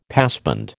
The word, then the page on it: passband: American English